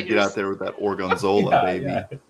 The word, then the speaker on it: Gorgonzola: How do you say it? Gorgonzola. – Yes.